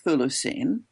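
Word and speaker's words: Chthulucene: So first of all, I pronounce it Thulucene, and it's a kinos.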